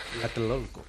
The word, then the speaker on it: Tlatelolco: Y en esta zona de nombre absolutamente impronunciable como es Tlatelolco. – Tlatelolco.